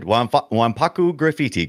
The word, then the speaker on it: Wanpaku Graffiti: Pretty good. Pretty good. Wanpaku Graffiti. Correct. Yep.